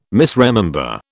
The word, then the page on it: misremember: British English